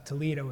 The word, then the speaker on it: Talitha: Talitha Kum is that phrase we read it, but it's pronounced Talita was her name.